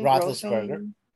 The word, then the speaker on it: Roethlisberger: Roethlisberger.